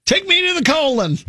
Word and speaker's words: Colon: Colon, Michigan, by the way. – Cologne, maybe? – It's even phonetically spelled out. It is colon.